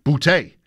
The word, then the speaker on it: Boutte: He's another one that used to go by a different pronunciation, Boutte.